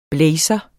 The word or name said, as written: BLAZER